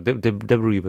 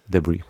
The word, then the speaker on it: Debris: Debris wird es, glaube ich. Das S wird, glaube ich, gar nicht ausgesprochen. – Ah, Debris. – Debris.